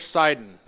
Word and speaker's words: Sidon: We have Tyre, Sidon. You might pronounce that in English, Sidon. That's fine. You can do that.